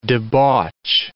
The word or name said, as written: debauch